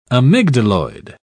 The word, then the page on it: amygdaloid: British English